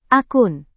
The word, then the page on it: akun: indonesian